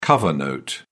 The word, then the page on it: cover note: British English